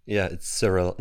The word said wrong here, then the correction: Cyrill: No, fuck. It's I pronounced it English. It's Kyrill. Kyrill in Germany.